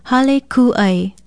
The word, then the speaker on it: hale kū'ai: Spell it H-A-L-E, hale, and K-U-okina-a-i. Then put a makron or kahako over the letter U. Pronounce it hale kū'ai. Hale kū'ai.